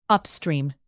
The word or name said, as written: upstream